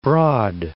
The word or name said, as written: French Broad